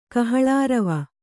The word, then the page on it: ಕಹಳಾರವ: kannada